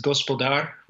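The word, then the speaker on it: gospodar (господар): The word is gospodar, which is the word for master or lord.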